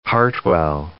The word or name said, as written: Hartwell